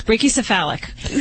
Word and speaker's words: brachycephalic: Is that one of those, what do they call that, bronchiocephalic? – Brachycephalus, right? Yeah. Did I say it right? – Pretty close. Brachycephalic. We could say pug-like.